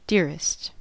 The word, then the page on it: dearest: American English